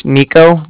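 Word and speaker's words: Niko: Yeah, my name's Nico. That's N-I-K-O. And I'm calling with the Bank of Cardiff. That's spelled C-A-R-D-I-F-F, as in Frank Frank.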